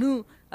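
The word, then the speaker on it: Yhú: Así que le pedimos el testimonio al padre Jorge Cóceres, que está en Nú, así se pronuncia en guaraní, pero bueno, es una localidad que se escribe YHUU, lo podemos pronunciar tranquilamente, que bueno, nos contaba esto.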